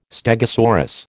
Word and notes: stegosaurus: American English

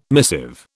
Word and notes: missive: British English

